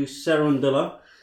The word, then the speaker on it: Cerundolo: Decent Miami Open, lost to Cerundolo, if I think I'm saying it right, Paul. – Yeah, sounds good.